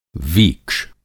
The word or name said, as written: वीक्ष